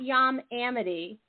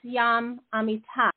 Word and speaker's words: Yam Amitay: So, again, Yam Amitai is spelled Y-A-M-A-M-I-T-A-Y, official. Yes. Guys, don't forget the A. His name is not Yam Amity. It's Yam Amitai. And it's a Y at the end, not an I. Okay. And or Georgie is G-O-R-G-I, official.